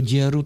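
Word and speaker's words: Győr: A když jezdím do Děru, tak tam bych to měl vyslovit. – Děr. – Do Děru? Děru. Tak tam bych to měl vyslovovat, tak jak to říkám, nebo oni... – Tak jak to říkáte, to je dobrý.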